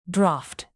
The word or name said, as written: draught